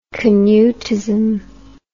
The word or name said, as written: Canutism